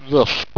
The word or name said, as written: rusp